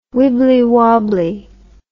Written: wibbly wobbly

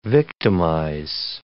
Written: victimize

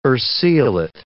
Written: urceolate

